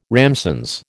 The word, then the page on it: ramsons: American English